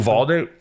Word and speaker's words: Uvalde: Listen, shout outs to Duvalde. What's the name? How do you pronounce that county in Texas? – Oh, Uvalde or something? – Uvalde.